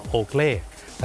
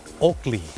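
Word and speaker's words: Oakley: คนไทยออกเสียงว่าโอเกลแต่เจ้าของภาษาไม่ได้ออกเสียงว่าโอเกลครับเขาออกเสียงว่าโอกลี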